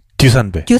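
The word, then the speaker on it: 듀산베 (Душанбе): 듀산베.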